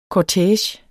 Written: kortege

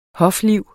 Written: hofliv

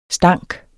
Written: stank

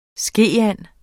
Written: skeand

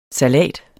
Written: salat

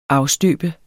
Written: afstøbe

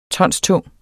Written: tonstung

